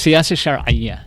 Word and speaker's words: Siyasa Shar'iyya: And pronounce it again so we can get it correct. – Okay, so it's Siyasa Shar'iyya, and Siyasa is statecraft.